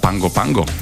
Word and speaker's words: Pago Pago: No a keď priletíme do hlavného mesta Pango Pango, ono sa to píše Pago Pago, ale teda málo našincov vie, že tá výslovnosť je tam iná, tak človek už z lietadla vidí taký dramatické scenérie, pretože americká Samoa je oveľa menšia.